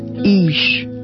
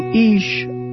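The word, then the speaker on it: eesh: Today's word is Ish, which means father, and is spelled E-E-S-H. A person might say, Do Ish Awawaita, which means her father is sitting there.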